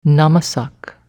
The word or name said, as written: namasak